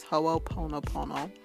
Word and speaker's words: Ho'oponopono: So it's Ho'oponopono,